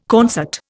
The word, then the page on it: concert: Indian